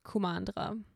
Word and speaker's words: Kumandra: Ähm, genau, also vor langer Zeit gab es das vereinte Land Kumandra.